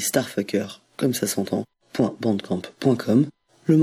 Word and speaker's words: STRFKR: On continue avec Starfucker, qui s'écrit désormais S-T-R-F-K-R,